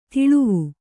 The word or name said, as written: ತಳಿವು